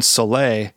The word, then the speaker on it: Soleil: The opening tune, Soleil, that's how you pronounce it, right? – That is correct.